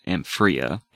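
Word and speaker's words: Empyrea: I believe it's pronounced Amphrea.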